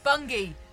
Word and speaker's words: Bungay: It's Bungie. – Bungie. – Yeah. – Bungie. – I know it's spelt Bungay, but it's Bungie.